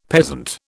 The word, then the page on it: peasant: British English